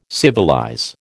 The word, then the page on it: civilize: American English